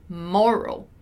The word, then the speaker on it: moral: Notice how in moral, our stressed syllable is mo, moral, right? While in the first word we have there, our stressed syllable is morale.